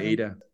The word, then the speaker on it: ADA: Cardano a- a- ADA is, or ADA, is that how you pronounce it? – Yeah, we say um, ADA.